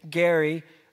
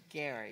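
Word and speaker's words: Gerry: Gary, which he always is trying to fool us because it's spelled Jerry, but it's Gary. – It's Gary, thank you.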